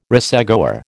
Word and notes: racegoer: American English

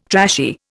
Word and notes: trashy: Indian

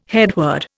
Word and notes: headword: Indian

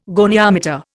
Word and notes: goniometer: Indian

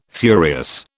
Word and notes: furious: British English

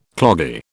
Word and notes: cloggy: British English

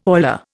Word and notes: oiler: Indian

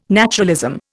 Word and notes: naturalism: Indian

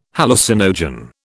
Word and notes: hallucinogen: British English

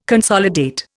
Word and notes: consolidate: Indian

